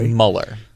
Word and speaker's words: Mueller: It's Mueller. – Oh, really? – Yes. Just letting you know. – M-U-E-L-L-E-R? – It's pronounced Mueller.